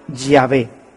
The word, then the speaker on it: Yahvé: De manera que este yo soy el que soy se pronunciaría o se diría Yahvé. Yahvé te envía. Así es como habría sonado en hebreo antiguo lo que Dios le dijo a Moisés en la zarza antigua, en esa zarza en el desierto.